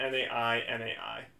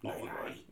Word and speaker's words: Nainai: N-A-I-N-A-I. – Nai-nai.